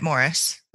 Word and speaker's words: Maurice: I think it's just pronounced Morris. – It's the British way. – Yeah, the British. I think that's how I Googled it. Also, I know Morris Manning, and his name is spelled Maurice, but he says Morris, and he said it was an English thing.